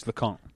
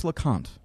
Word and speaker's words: Leconte: by Patrice Leconte. – Patrice Leconte.